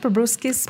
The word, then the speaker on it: proboscis: Yeah, they think it's likely the offspring of, as you mentioned, a proboscis. Am I saying it right? Proboscis? – Proboscis, yeah.